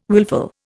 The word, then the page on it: wilful: Indian